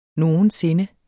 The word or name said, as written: sinde